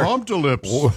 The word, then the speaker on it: Humptulips: I wonder if they pronounce, maybe it's pronounced Hump to Lips. – Boy, Hump-to-Lips is almost more offensive. – Yeah. – I like it. Yeah. But yeah, it's Humptulips. Yeah, Hump-to-Lips sounds like a purple giraffe on a kid's TV show.